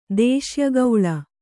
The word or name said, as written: ದೇಶ್ಯಗೌಳ